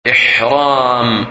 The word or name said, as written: Ihram